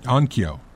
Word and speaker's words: Onkyo: I'm looking at a Denon and, oh, I'm going to mispronounce it, Onoko? – Onkyo.